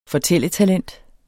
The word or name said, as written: fortælletalent